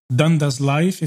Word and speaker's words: Dundas Life: company Dundas Life, if I pronounce that correctly. – Yep.